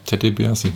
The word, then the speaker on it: Ted DiBiase: Ersten ist es John Cena, im zweiten ist es der gute alte, das muss ich gleich nochmal gucken, der Ted DiBiase, ist das richtig ausgesprochen? Oder? – Naja, Ted DiBiase.